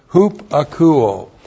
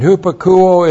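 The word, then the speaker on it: hupakouo: Looks like that in the Greek, H-U-P-A-K-O-U, long O, hupakuo. And hupakuo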